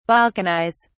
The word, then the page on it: balkanize: British English